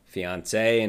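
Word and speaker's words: fiancé: Now, do you know how to pronounce them? – Fiance and fiance. – Yeah, perfect.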